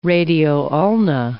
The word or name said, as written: radio-ulna